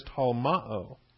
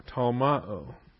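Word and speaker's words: tolmaō: There's actually a brand new term to this book that's being introduced here, and the term is talmao. Talmao, daring. T-O-L-M-A-O, talmao. T-O, that's a short O, omicron. Tau, omicron, lambda, mu, alpha, omega. T-O-L-M-A-O.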